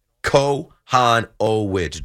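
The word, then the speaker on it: Kochanowicz: They have to come to the ballpark ready to get after Jack Kowanowicz. Kowanowicz. You know, when you go to Baseball Reference, you can go to the pronunciation. Kowanowicz.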